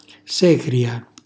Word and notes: secria: Calabrese

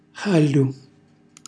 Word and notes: xàļļu: χàļļu

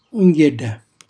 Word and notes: unghjella: Calabrese

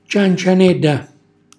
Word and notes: ciancianella: calabrese

